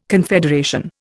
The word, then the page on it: confederation: Indian